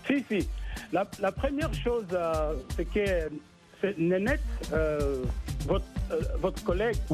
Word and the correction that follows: Nanit: Je prononce bien les noms, c'est Nénette. – Nanit, c'est pas grave. – C'est Nanit.